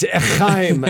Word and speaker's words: Haim: It's Haim.